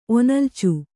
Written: ಒನಲ್ಚು

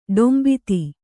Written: ಡೊಂಬಿತಿ

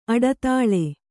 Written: ಅಡಿದಳ

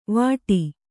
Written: ವಾಟಿ

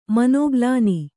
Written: ಮನೋಗ್ಲಾನಿ

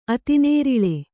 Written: ಅತಿನೇರಿಳೆ

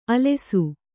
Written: ಆಲಾಷ

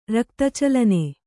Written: ರಕ್ತಚಲನೆ

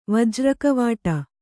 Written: ವಜ್ರಕವಾಟ

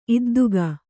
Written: ಇದ್ದುಗೆ

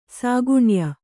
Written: ಸಾಗುಣ್ಯ

